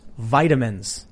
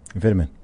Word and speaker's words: vitamin: Joe Sosnowski says, that's not how you pronounce vitamins. – Vitamin. – Yeah. – Vitamin. – Vitamin. – Vital mineral. – I abstain. You're both right.